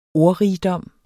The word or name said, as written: ordrigdom